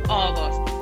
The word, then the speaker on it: August: Cuidado con August, porque se escribe A-U-G-U-S-T, August, ¿vale? Pero no tiene ni la A ni la U. La O. August.